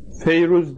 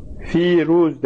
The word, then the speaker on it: Firuz: Tabi İranlılar Feyruz demezler. Firuz derler. Fakat Arapçaya geçince böyle Feyruz diye harekelemiş buraya. Aslında Feyruz kelimesi Farsçadır. Ama Arapçaya geçmiştir. Farsçası P harfiyledir. Fasih olanı. Piruz'dur aslı.